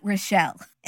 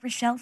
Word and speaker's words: Richelle: And then it says, I think you are the right Rochelle in the letter. – How many Rochelles are there? Because it's spelled R-I-C-H-E-L-L-E, like Michelle, but with an R.